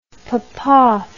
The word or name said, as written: Popov